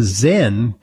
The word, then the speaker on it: Xen: It's the Zen by Nuvana. That's spelled X-E-N.